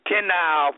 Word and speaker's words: Tennile: But the truth of the matter, he was born on the outskirts of Sandersville near two small villages, one called, I pronounce it Ten Isle for a reason, T-E-N-N-I-L-E.